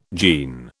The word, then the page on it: jean: British English